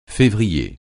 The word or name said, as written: février